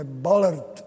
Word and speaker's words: Ballard: Ballard, správně se vyslovuje, Ballard, objevitel vraku Titaniku a tak dále.